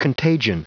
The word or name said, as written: contagion